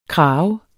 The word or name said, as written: krage